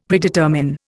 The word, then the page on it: Predetermine: Indian